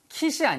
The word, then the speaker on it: quit: 발음 하나 주의하세요. 이 단어가 quit이 아니고요. quit이에요. Q 다음에 U가 오잖아요. 그러면 영어 사전을 찾아보시면은요. 여기에 W 발음이 있습니다. 90% 이상. 그래서 quit. 이렇게 입술이 나갔다고 들어오면서 하시면 돼요.